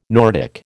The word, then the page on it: Nordic: American English